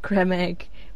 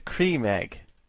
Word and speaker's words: Creme Egg: No, it's a cream egg. – Yeah, it's a creme egg. – No, it's not. Honeydew. – It's a cream egg, you idiots. – It's a cream egg.